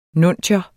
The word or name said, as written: nuntius